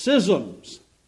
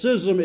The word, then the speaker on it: schism: In English, schism is the first pronunciation. In Greek, the word is schism, and that's the second pronunciation in English. Okay, so you can say either one, but schism's correct.